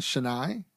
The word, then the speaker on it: Chennai: Bengaluru and Chennai.